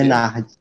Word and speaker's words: Bernardes: É Bernardes que se pronuncia? – Bernardes. – Bernardes. É.